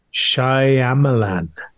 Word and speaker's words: Shyamalan: Night Shyamalan. – Oh, nice, nice. – I think I nailed that for once in my life. I used to just Night Shyamalan. – M. Night Shyamalan.